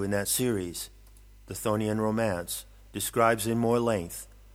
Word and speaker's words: Chthonian: Chthonian is pronounced Chthonian, and it refers to the original foundations of the planet Earth.